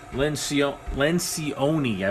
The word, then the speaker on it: Lencioni: The author is Patrick Lencioni, I believe that's how you say it. That's L-E-N-C-I-O-N-I.